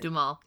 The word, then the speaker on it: Duhamel: It came out in 2010, starring Kristen Bell and Josh Duhamel, right? That's how you say it? – Duhamel, yeah.